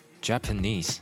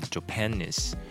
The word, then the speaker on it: Japanese: 比如像刚刚我有讲到一个字叫 Japanese，日本人或者是日语嘛，这个字在台湾很多人念会念 Japanese，啊、呃，这个重音有点怪怪的哦。如果你去听外国人讲，或者是你去字典点那个发音的话，你会发现这个字的重音在最后一个音节，所以它应该念 Japanese，Japanese。